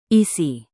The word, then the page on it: isi: indonesian